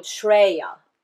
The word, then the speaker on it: Shreya: The English pronunciation would be P R E Y A, Preya. And there is the one that's called Shreya, S H R E Y A, Preya and Shreya.